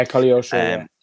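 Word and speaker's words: Koleosho: Is it Colioso, how you say it? I think it, yeah, Colioso.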